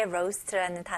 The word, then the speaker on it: roast: roast 같은 경우는 이 r 발음을 명확하게 해주셔야 됩니다. 혀가 천장을 닿으면 그 순간에 L 발음이 되거든요. 그래서 천장을 닿으면 안 돼요. roast, 오케이? 혀가 뒤로 당기는 느낌으로 꼬부리면서 roast, roast, 오케이? 그리고 이거는 짧은 모음이 아니고 살짝 긴 모음입니다. 그래서 roast가 아니고 roast. 제 입모양 보이시죠?